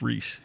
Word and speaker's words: Rhys: Yeah, it's Rhys. He's He's phenomenal. – It should be spelled like Reese should be spelled.